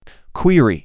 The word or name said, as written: Query